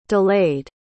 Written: delayed

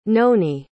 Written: noni

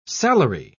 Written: salary

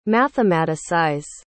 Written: mathematicize

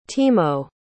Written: Timo

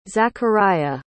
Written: Zakariyya

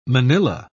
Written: manila

